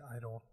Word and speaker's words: I-DLE: Yeah, but then in English they they spell it as like G idol, So it's like idol. – but I D L E.